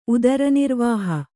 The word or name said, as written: ಉದರನಿರ್ವಾಹ